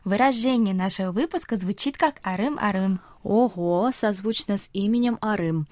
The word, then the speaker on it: 알음알음: Если имя Арым пишется как А Рым, то слово арым-арым пишется как аль-им, аль-им, а читается оно через р, то есть арым-арым, а не аль-им-аль-им, как пишется, потому что риэль из первого слога встретился с гласной буквой «и» во втором слоге.